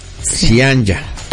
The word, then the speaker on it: Zyanya: Se pronuncia Cianya. – Ah, es correcto. – Sí, y se escribe con Y. – Sí, Z-Y-A-N-Y-A.